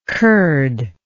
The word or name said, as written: curd